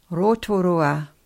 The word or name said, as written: rotorua